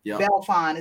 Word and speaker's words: Belfon: Belfon. It's almost like French, Marlon Belfon. Marlon Belfon.